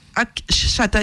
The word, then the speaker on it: Akshata: Mais il a su faire fortune, et puis il a une grosse fortune qui est due à son mariage avec donc sa femme Ashkata. Akshata, excusez-moi, j'écorche son prénom,